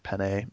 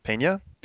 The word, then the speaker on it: Peña: End of Watch comes out, which is the Jake Gyllenhaal, Mike Peña or Pene, or I don't know how you pronounce it. Peña. – Doesn't have a thing over the E. Peña. – It's of course doesn't have a thing over the E. It's Peña.